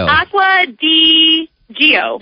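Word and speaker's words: Acqua di Gio: You said Acqua Digio. – Yeah, like it's spelled, right?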